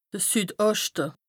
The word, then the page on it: de Süd-Oschte: Bas Rhin; Strasbourg